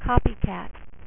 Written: Copycat

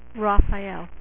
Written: Raphael

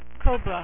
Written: Cobra

